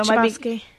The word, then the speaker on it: Chbosky: If I say his last name wrong, Chbosky? Or it's the H is silent. – Stephen Chbosky.